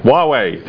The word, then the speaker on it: Huawei: Speaking of mispronunciation, let's talk about Huawei. – Huawei. – Thank you.